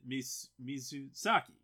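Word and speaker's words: Mizusaki: So, and it's directed by Junpei Misusaki, who... Misusaki. Yeah, I said it right.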